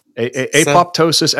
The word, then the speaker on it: apoptosis: Actually, there's debate as to whether the second P gets pronounced because technically it's apo is the is the prefix, and then PTOSIS is the Greek word. So it could be apoptosis.